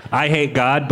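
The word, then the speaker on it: Eyehategod: I Hate God, but it's spelled E-Y-E. Hate God.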